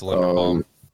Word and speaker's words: Linderbaum: Remington, the Remington Award was won by Tyler Linderbaum. Sorry if I butchered that name. – I think it's Linderbaum.